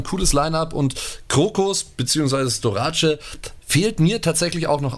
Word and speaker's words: Storace: wird ja auch gerne mal Storis ausgesprochen, ist aber gebürtiger Malteser. Also jetzt kein Sanitäter, sondern eben auf Malta geboren. Deswegen Storace.